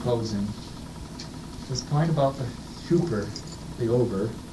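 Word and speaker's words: Huper (ὑπέρ): Huper, the Greek says, H-U-P-E-R, as we would spell it.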